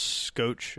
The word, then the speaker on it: Schoch: His name is pronounced Robert Schoch, but it's pronounced Scoch. It's like S-C-H-O-C-H. But, oh, okay. Anyways, it's pronounced Schoch. So Robert Schoch was a geologist, and he's an associate professor of natural science at Boston University.